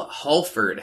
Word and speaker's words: Hallford: I am joined today by game designer, writer, film producer, Neal Hallford.